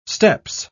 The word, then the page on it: steppes: British English